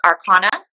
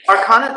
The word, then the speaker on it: Arcona: It's Arcona. – Arcona, that's it.